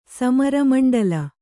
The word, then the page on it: ಸಮರಮಂಡಲ: Kannada